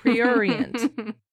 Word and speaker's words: prurient: But man, it's hard to pronounce. Prurient. You know how it's spelled? It's a crazy word. P-R-U-R-I-E-N-T.